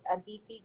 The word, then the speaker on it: Aditi: It's Aditi Gupta.